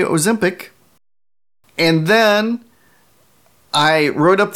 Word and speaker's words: Ozempic: Listener Josh writes in and he says, long O in Ozempic. Do your research. Followed up by listener Matt, who says, Ozempic, like Olympic.